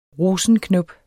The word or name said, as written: rosenknop